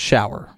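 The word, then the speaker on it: Schauer: It's actually pronounced Shower. – Shower? – Yeah. – Oh, it is? Yep. Okay. That's weird. – I know. I looked it up. – It's not spelled like shower.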